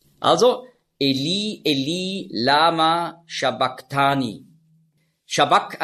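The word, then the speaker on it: sabach: Also nicht Shabbach, sondern Shabbak.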